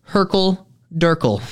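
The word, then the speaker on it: hurkle-durkle: It is pronounced Herkel Durkel.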